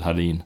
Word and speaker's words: Holland: It is, but I still had to decide on that, because that's not how we pronounce it here. We pronounce it Halin. – Yeah. – And for many years, I said Halin in English.